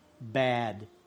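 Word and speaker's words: Bad (בד): Bad. – Bad. Bad. – That's how it's spelled too, B-A-D. – Jo, takhle opravdu to je jakoby, bad. Jako to slovo bad v angličtině, co znamená jako zlý, špatný, zlý.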